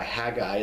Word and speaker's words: Haggai: So when you see Haggai, all right, H-A-G-G-A-I, Haggai is pronounced Haggai. Just pretend there's one G there. Or the word Sukkoth, S-U, and then some translations say C-C-O-T-H, some say S-U-K-K-O-T-H, same thing.